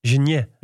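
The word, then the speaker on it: Geniets: Hoe spreek je deze naam uit, denk je? Geniet... Geniet. Het is een Luxemburger, maar mijn Luxemburg is niet zo sterk. – Michel en José hadden het op Geniet.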